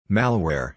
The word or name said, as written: Malware